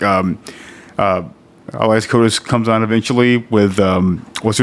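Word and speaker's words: Elias Koteas: It's Elias Kotas.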